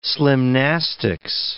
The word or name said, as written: slimnastics